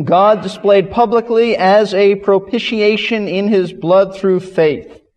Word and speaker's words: propitiation: But it is propitiation, and it is throughout the New Testament in connection with the cross.